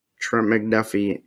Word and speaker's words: Trent McDuffie: And which now I'm double checking, like Trent McDuffie.